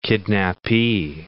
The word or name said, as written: kidnapee